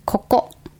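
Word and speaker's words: Koko (ここ): How do you say here? – Koko. – Chocolate? – Koko. – Yes. K-O-K-O. Koko.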